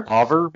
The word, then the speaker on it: Hauver: H-A-U-V-E-R. – Hauver, maybe? – Hauver, yeah.